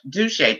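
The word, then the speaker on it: Dauchsy: It's Douche. Douche. That's how he pronounced it. It's D A U C H S Y.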